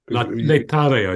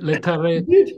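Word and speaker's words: Laetare: Letare, I think.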